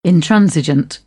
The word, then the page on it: intransigent: British English